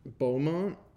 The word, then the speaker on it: Beaumont: But I cheated for this episode, and this article comes from Mark Beaumont. Is that how you say it? Yeah. And his article that is the ten. 10 most boring video games ever. Beaumont.